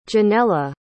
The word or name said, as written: Jenella